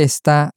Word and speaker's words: está: So, the is or it is that we've been using is pronounced está. – Está. – Está. – Está. – And the emphasis is really very much on the sta at the end.